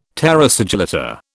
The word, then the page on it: terra sigillata: British English